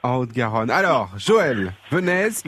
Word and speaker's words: Venez: Donc on prononce le Z. – On prononce et le Z. – Et ça s'écrit comme le verbe venez. On prononce le Z. – Donc, et vous le prononcez Venesse, avec un peu de voilà. S.